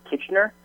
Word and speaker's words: Kitchener: I think it's pronounced Kitchener Toronto. Kitchener, yeah, absolutely.